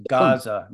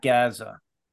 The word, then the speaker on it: Gaza: It is pronounced Gaza, not Gaza. Contrary to what the BBC and the CBC say, it is Gaza, not Gaza.